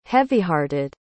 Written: heavyhearted

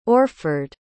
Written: orford

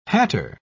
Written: hatter

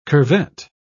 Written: curvet

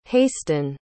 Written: hastin

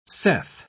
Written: saith

